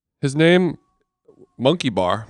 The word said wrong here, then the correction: Munkhbayar: No. Monk Bayar. Hold on, let me spell it. Go back. For you guys at home, it's MUNKHBAYAR.